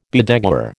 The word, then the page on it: bedeguar: American English